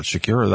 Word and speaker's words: Shakira: It was therefore appropriate for the halftime show to have a Latin beat with Shakira, I guess, what, Shakira, that's how you pronounce it, and J-Lo as headliners.